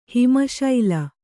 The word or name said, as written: ಹಿಮಶೈಲ